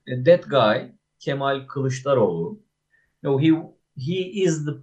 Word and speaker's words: Kılıçdaroğlu: Kılıçdaroğlu, is that how you say his name? – Yes. Kılıçdaroğlu.